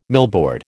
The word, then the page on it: millboard: American English